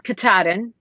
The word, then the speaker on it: Katahdin: Katahdin.